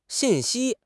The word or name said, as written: xin4 xi1